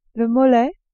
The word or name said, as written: mollet